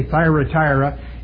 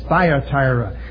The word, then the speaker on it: Thyatira: I've been for years putting another R in it just out of memory. And so I say Thyatira, and it's Thyatira.